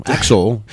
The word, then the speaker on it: Axel: Especially when I mispronounce it Alex. So Axel was, yeah.